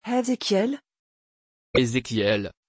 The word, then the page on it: hezekiel: French